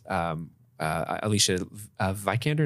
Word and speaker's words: Alicia Vikander: And then the Gollum character, which is, Alicia Vikander, is that how you pronounce her name? – Mm-hmm.